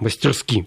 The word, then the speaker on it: мастерски: Но, тем не менее, пилот посадил вот Ан-26 на Квайрисе столь мастерски, или, как правильно говорить, мастерски, правильно, мастерски, вот так да. Правильно надо говорить. Uh-huh. Но в обиходе обычно все люди говорят мастерски.